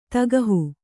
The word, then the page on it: ತಗಹು: tagahu